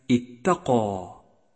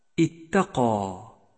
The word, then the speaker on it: اتقى: ثالثا، جاء حرف الألف مفخما في كلمة اتقى اتقى، لأنه سبق بحرف مفخم والألف تتبع ما قبلها في التفخيم والترقيق، بينما جاء حرف الألف مرققا في كلمة الناس الناس، لأنه سبق بحرف مرقق.